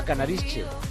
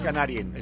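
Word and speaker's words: Kanarische: A Canarische. Canarische. No, no es Canarien.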